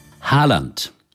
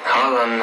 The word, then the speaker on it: Haaland: Haaland, uh, I, I like it more to be honest. Also, zwei A sind ein O. So einfach ist das eigentlich in Norwegen mit der Aussprache.